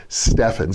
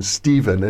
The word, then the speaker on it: Stefan: Sometimes I call him Stephen, and I've known him for 40 years, but Stefan is the correct pronunciation. He tolerates people like me calling him Stephen from time to time.